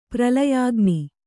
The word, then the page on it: ಪ್ರಲಯಾಗ್ನಿ: pralayāgni